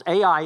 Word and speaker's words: Ai: And so when Jericho falls, it's not as though the city that we're going to learn about here in chapter 7, Ai, literally Ai, and that's how you say it, Ai, wasn't going to come out of their walls and come to the rescue of those in Jericho.